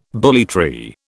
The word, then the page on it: bully tree: British English